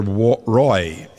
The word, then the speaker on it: Roy: Brother Roy, as we would pronounce it in English, and I won't try and say it in French, and he is a good friend.